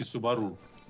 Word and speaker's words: Субару: А почему ты говоришь Субару, если Субару? – Какая в английском нет ударения. Субару. – Слушай, по-японски Субару.